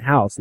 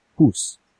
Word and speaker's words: house: In 20 pages is I've used house, which for them, they don't pronounce ow, they pronounce ooh. So OU is not house, it's hoos.